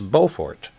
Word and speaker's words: Beaufort: the two ways of pronouncing B-E-A-U-F-O-R-T. In North Carolina, it's Beaufort, but in South Carolina, it's Beaufort. So here we go to Beaufort, South Carolina, 960 AM.